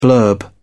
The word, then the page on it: blurb: British English